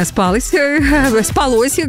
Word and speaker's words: спалось: Я очень надеюсь, что всем остальным вам прекрасно спалось, как говорят, вот так надо правильно ударение ставить.